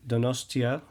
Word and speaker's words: Donostia: I do want to say that there is a video on YouTube about this particular event in Donostia. Is that how you pronounce it? – That is the Basque name of the city.